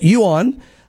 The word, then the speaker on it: Yuan: So apparently it's pronounced Yuan. And that's how you say it.